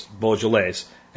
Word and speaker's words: Beaujolais: And Beaujolais is French, so it's Beaujolais.